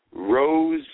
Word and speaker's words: Rosenbluth: It's Dave Rosenbluth. You got to let the U's breathe.